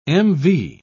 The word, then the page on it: mv: British English